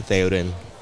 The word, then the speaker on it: Théoden: I pronounce it Théoden, and I myself am mispronouncing it. My parents pronounce it Théoden, and it's from Lord of the Rings.